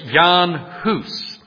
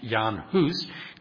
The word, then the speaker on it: Jan Hus: His name was Jan Hus. Some pronounce it Jan or John Hus, but it was Jan Hus.